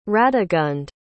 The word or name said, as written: radegund